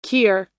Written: kier

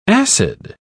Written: acid

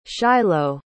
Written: shilo

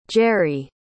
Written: jeri

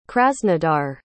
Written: krasnodar

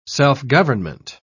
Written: self-government

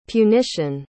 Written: punition

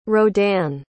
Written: rodin

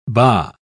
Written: baa

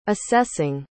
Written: assessing

